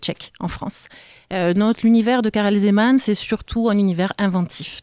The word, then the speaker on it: Karel Zeman: Alors désolé pour la prononciation, Matej, il s'appelle Karel Zeman. – Karel Zeman, c'était bien.